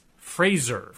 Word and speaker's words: Fraser: How do you pronounce, we asked, how do you pronounce F-R-A-S-E-R Simpson's first name? Mike Knofnagel says, Mr., yes, he's a man, Simpson pronounces his name, his first name to rhyme with the word razor. It's a Z sound, not an S, and not a Z sound like the Kelsey Grammer character. So it's Fraser.